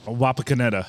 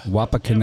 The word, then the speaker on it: Wapakoneta: Wapakoneta. – Wapakoneta.